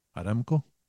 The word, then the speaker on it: Aramco: Aramco, isn't it? – Aramco, yeah. – Yeah, Aramco. Well, how's it pronounced, was it? – Aramco? – Yeah.